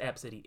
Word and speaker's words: Abcde: It's pronounced Ab City. It is spelled A-B-C-D-E.